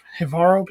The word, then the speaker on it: Jivaro: This comprehensive work intricately details the utilization and rituals involving ayahuasca by the Jivaro people. Is that how you pronounce it? – Yeah.